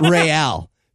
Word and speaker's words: Real: I love that they pronounced it Real. Isn't that, that's just Spanish for royal.